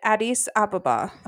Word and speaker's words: Addis Ababa: I think Phil said it, Addis Ababa. – Okay. – Because that's not how I would have said it, Addis Ababa.